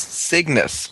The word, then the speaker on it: Cygnus: It shows Giant Foot being used on Dragon and Freeze being used on Kaiknos. Or wait, no, that's not how you spell it. It's Cygnus.